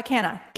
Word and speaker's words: Kanna: That's how you say your last name? Madison Kanna? – Yeah. Kanna. Kanna.